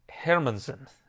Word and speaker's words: Hermansson: Jack Hermansson, as he told me it is pronounced.